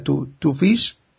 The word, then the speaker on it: Twofish: no sé si se pronuncia TWFISH. Uh-huh. Eso me lo tienes que decir tú. – Sí, puede ser una interpretación, sí, más o menos es la pronunciación, no vamos a ser muy tiquismiquis en eso. No, es correcto, sí, me suena.